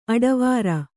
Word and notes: ಅಡವಾರ: Kannada

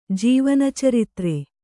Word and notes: ಜೀವನಚರಿತ್ರೆ: Kannada

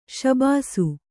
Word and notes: ಶಾಭಾಸ್: śabhās

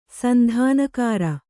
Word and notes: ಸಂಧಾನಕಾರ: Kannada

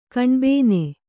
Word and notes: ಕಣ್ಬೇನೆ: kaṇbēne

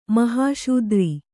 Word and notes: ಮಹಾಶೂದ್ರ: mahāśudra

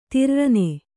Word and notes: ತಿರ್ರನೆ: Kannada

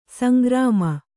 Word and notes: ಸಂಗ್ರಾಮ: sangrāma

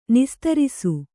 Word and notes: ನಿಸ್ತರಿಸು: nistarisu

